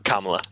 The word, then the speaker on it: Kamala: And honestly, if he didn't put, is it Kamala? Okay, I think Kamala. – Kamala.